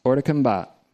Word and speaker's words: hors de combat: If you're an obedient slave to sin, on the other hand, you are, French term, hors de combat, that's how it's pronounced, hors de combat. Doesn't mean whores of combat.